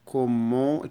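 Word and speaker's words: comment: عشان يسأل عن صحة زميله بيقول له كومون، كومون التي لا تنطق والان بتنطق من الانف.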